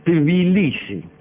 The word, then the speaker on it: Tbilisi: T y después Bilisi, no sé cómo se pronunciará, me parece que Tbilisi. ¿Y por qué la primera I no la pronuncio? Tbilisi. Uh-huh. Sería mucho mejor.